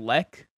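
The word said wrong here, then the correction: Lecque: Is that his name? Jalen LeQue Leck? I don't know how you say his name. – LeQue. – Oh. Jalen LeQue, I think he went ham.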